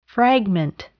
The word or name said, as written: fragment